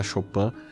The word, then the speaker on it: Chopin: E eu reencontrei aqui algumas coisas do Chopin, se escreve Chopin, né, Frederic Chopin, mas se fala Chopin, que é ele foi um pianista, enfim, ele tem diversas obras bem conhecidas para piano, algumas valsas bem conhecidas, assim.